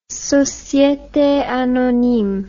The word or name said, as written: societe anonyme